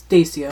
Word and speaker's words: Dacia: Dacia or... – Dacia.